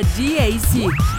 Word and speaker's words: GAC: Se escribe GAC, pero se pronuncia GAC.